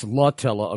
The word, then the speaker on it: Emily Latella: And we have a listener in Alabama who is correcting me on the pronunciation of, I thought I was saying Emily Latella. What was I saying? But it's Latella, according to this this listener.